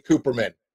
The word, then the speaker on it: Kuperman: It's Katie Kuperman. Right, Katie? – Kuperman. – Oh my gosh. Goodness gracious. There it is. Katie Kuperman.